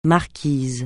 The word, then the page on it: Marquises: francais